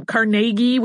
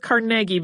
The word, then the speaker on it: Carnegie: Um, for the purposes of this, since it is all about his life, we're going to go with Carnegie because that sounds the closest to the way uh, his family seems to pronounce it.